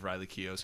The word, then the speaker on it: Keough: It's just from the perspective of Riley Keough's character. – It's pronounced Riley Keough.